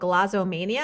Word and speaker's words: glossomania: It's called glossomania. Am I pronouncing that correctly? – Yeah.